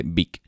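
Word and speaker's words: Bic: Pasa, él quería llamarlo Beach, pero eh, Beach, que era su apellido, pero claro, le dicen, bueno, llamarlo Beach, sabiendo lo que significa Beach en inglés, esto no puedes lanzarte a un mercado eh, con tu apellido porque allí es eh, prácticamente eh, la pronunciación de tu apellido es sinónimo o es un significado de prostituta, con lo cual le convencen para sacar la H y, y así es como nace Vic.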